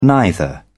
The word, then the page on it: neither: British English